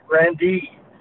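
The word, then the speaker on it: Grande: Well, it was the Grandee Ballroom. Grandee. That's the way we pronounced it.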